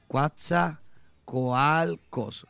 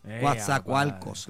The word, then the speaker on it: Coatzacoalcos: Coatzacoalcos. – Ah, Coatzacoalco. Coatzacoalco. Es un nombre azteca. Yeah. ¿Viste? Dios es mío. Una palabra azteca. Escríbelo como se pronuncia, varón. – Coatzacoalcos. Coatzacoalcos.